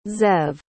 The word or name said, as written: zev